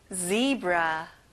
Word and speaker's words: zebra: 앞에 z 발음을 정확하게 해보세요. zebra.